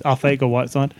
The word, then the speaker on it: Arcega-Whiteside: Apparently, you don't pronounce the C. It's just Arcega-Whiteside.